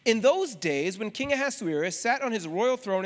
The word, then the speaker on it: Ahasuerus: We read, if you just go to Esther chapter 1, verses 1 through 3, we read, "Now in the days of Ahasuerus," that is how that name is pronounced, Ahasuerus.